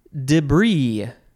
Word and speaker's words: debris: It is debris or debris. So let's see. We got debris, debris, debris. You can emphasize the day or not.